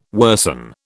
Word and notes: worsen: British English

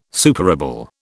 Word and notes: superable: British English

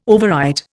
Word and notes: overwrite: Indian

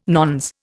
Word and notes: nones: Indian

